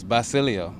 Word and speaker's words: Basilio: It's Basilio, so it rhymes with my first name.